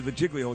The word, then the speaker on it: Giglio: This is the Giglio. It's different. – Giglio. – Giglio, thank you.